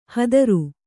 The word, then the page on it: ಹತ್ತರ: hattara